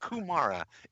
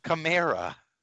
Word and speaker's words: Kamara: It's not Kumara. It's Kamara.